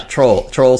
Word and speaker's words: Trole: It's spelled T-R-O-L-E Square. Thank you very much. – Yeah. Troll Square. Which is not confusing at all.